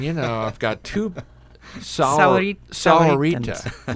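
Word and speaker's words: Sahuarita: Is it improving my pronunciation? – Yes. – Sahorita. – Yep. Sahorita.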